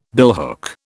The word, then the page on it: billhook: British English